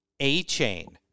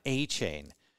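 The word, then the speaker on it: Achane: It's Devon A Chain. Talk about guys whose names don't look like they pronounce. Yeah. This is going to take a while to learn. Devon A Chain.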